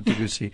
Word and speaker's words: Debussy: Am I saying that right? Debussy. – Yeah, Debussy.